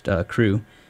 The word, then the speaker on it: CHRU: known as CHRU, pronounced CREW, is a student-led program that provides life-saving harm reduction resources to the public of Chapel Hill.